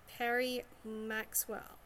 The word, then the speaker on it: Peri Maxwell: So, before I get any further excited and I overdo this too much, please all welcome Peri Maxwell.